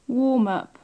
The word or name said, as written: warm up